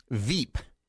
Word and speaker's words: VEEP: VEEP. V as in Victor. E-E-P. Rhymes with Jeep.